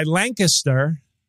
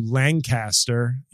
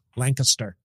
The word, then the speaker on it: Lancaster: So by Lancaster, Pennsylvania, as opposed to Lancaster, you fucking turds who don't know how to say it right. It's Lancaster, the home of the Amish.